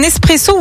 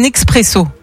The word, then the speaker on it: espresso: Alors tiens, est-ce que tu dis un espresso ou un expresso ? – Pour le café ? – Ouais. – Espresso. – Espresso, ouais. Étymologiquement, on ne prononce pas le X. L'origine du mot vient de l'italien esprimere.